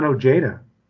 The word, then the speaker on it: Ojeda: I think one of the reasons that I use the name Ron Ojeda, really, for, you know, for 50 years or plus years of my life. And that's how my father pronounced it, not Ojeda.